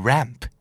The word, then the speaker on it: ramp: ramp และ lamp สะกดเหมือนกันเปียบเลยยกเว้นเปลี่ยนตัว R เป็นตัว L ตัว L เป็นตัว R นะครับทางลาดคือ R-A-M-P ramp ถ้าเกิดโคมไฟหรือโป๊ะไฟคือ L-A-M-P